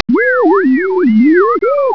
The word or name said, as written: where